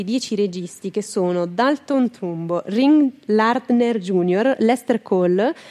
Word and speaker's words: Trumbo: Questa cosa è stata fatta solamente con questo Trumpo, si pronuncia, penso. Donald Trumpo. – Non è Donald, è Dalton Trumpo. – Dalton Trumpo. – Trumbo.